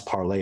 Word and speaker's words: Parler: And it also bothers me that it's pronounced parlor because parlay is French for the verb to speak. And so to hear everyone pronounce parlay as parlor just irritates me on a deep level.